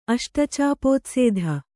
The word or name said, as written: ಅಷ್ಟಚಾಪೋತ್ಸೇಧ